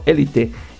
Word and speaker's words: Élite: Hablando de Dana Paola, pues resulta que bueno, la aparición de la cantante y actriz mexicana en el drama adolescente Elite. ¿Cómo se dice? ¿Elite o Élite? Es que los españoles pronuncian como va, ¿no? Élite.